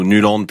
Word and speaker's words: Nyland: Parce que je trouve que Nyland ou Nuland, puisqu'il faut le prononcer comme ça, fait une compétition absolument incroyable.